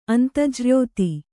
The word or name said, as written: ಅಂತಜ್ರ್ಯೋತಿ